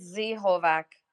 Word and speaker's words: Z Hovac: Z Hovac or Zovac? How do you pronounce it? – It's Z Hovac.